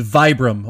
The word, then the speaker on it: Vibram: And the company that developed the five fingers is Vibram, or it's spelled V-I-B-R-A-M, Vibram.